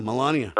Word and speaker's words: Melania: His wife, how do you say her name, Melania? – Yeah, Melania.